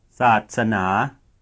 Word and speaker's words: ศาสนา: Satsana. – What is the pitch contour of the last syllable? Listen again. – ศาสนา The answer is low rising. You would expect high pitch contour normally on the last syllable.